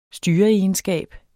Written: styreegenskab

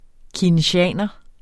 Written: keynesianer

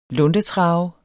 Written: luntetrav

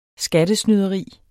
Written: SKATTESNYDERI

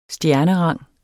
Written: stjernerang

